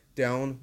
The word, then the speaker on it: don't: don't worry about someone یعنی نگران کسی نباش کاری ازش بر نمیاد don't به زبان انگلیسی don't تلفظ نمیشه ما میگیم don't و سعی میکنیم تی آخر رو تلفظ نکنیم